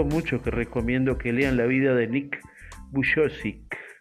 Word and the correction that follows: Vujicic: Creo que se pronuncia así, Nick Bu perdón. Eh, Nick Vujicic eh, fue un, muchacho, un joven que nació con, con, una discapacidad que en este momento no recuerdo qué era.